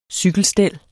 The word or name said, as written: cykelstel